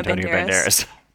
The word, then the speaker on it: Banderas: Also realize there's an, we're saying it wrong. There's an S in his name. – Antonio Banderas. – Yeah. – Banderas. – There's an S at the end. S, not Z. Antonio Banderas.